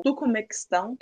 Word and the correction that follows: Turcomenistão: É um nome muito difícil, Turcomequistão. Até, já nem sei já tô pronunciando mais certo. esse, o Turcomenistão, como eu falei antes, ele tá na Ásia Central lá, né?